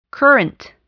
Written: current

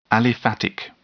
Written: aliphatic

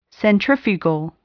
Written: centrifugal

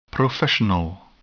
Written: professional

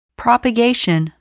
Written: propagation

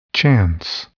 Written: chance

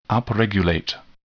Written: upregulate

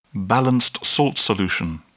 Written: balanced salt solution

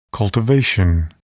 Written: cultivation

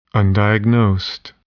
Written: undiagnosed

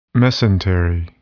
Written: mesentery

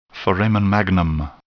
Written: foramen magnum

